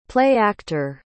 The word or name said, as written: play-actor